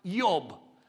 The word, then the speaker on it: Job: Now, Job, by the way, in the Hebrew, it's pronounced Yob.